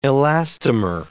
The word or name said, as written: elastomer